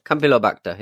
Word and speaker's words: Campylobacter: How do you say that? – I've not got the story up, but I think it's Campylobacter. – Campylobacter, yeah.